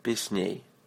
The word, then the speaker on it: Песней: Say ней. Песней. Accent on the second syllable.